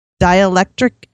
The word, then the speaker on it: dielectric: The second would be to try what a former auto mechanic friend suggested, that I use dielectric grease.